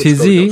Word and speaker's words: Taizé: Ah, Taizé, ja, weiß ich, weiß nicht, Franzosen halt, äh, keine Taizé, Taizé, so wird Taizé, ausgesprochen.